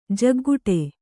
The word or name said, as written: ಜೋಕೆಗುಟ್ಟು